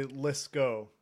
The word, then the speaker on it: Litzkow: It, you would think from the way it's spelled, it's actually Litzkow, which Litzkow. Okay. Sorry. Litzkow. – Yeah, the W is just there to mess with people.